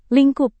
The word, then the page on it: lingkup: indonesian